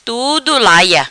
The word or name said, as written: Tudo Laia